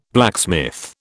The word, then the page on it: blacksmith: British English